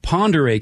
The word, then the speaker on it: Pend Oreille: Now, Ponderay County, okay, by my estimation, it's one of the two most mispronounced county names in the Evergreen State, the other being Wakayakum.